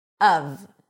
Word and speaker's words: of: Okay, it's pronounced, when emphasized, of, with a cup sound and a V sound. Even though it's spelled with an F, it's a V.